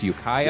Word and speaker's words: Ukiah: I've been informed it's pronounced Ukiah.